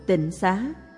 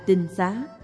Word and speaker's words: tinh xá: Thường thường người ta nói tịnh xá, nếu đọc đúng âm chữ Hán là tinh xá, tức là trong nhà đó rồng rạc người tu, rồng rạc người thanh tịnh, rồng rạc người giữ giới của Phật.